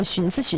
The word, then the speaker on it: 寻思: 然后有的时候叠在一起用的时候，你也很标准，说那我寻思寻思。 – 啊，那我寻思一下吧。 – 啊，对对，你看，你就是很自然的就把那个正确读音带出来了。但是大多数咱们东北可能都是说我寻思啊，都寻思一下的。